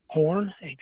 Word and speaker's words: Horne: Horn, HORNE.